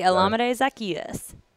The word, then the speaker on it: Olamide Zaccheaus: And Olamide Zaccheaus, am I saying that right? – Oh, yeah. OZ, okay. Olamide Zaccheaus.